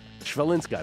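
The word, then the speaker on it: Chwalińska: Shvalinska, I believe it's pronounced.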